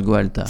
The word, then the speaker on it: Gaolta: Et le groupe qui s'écrit Gaolta, mais qui se prononce Goalta.